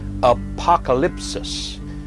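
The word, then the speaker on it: Apokalypsis: is pronounced Apocalypsis, or Apocalypse.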